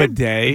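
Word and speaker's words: Badie: Tyler Bidet, I think is how you say his name. – Bidet. – No, I'm not joking. I believe that's how you say his name. – Tyler Bidet. – B-A-D-I-E. I think it's Bidet.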